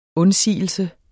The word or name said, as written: undsigelse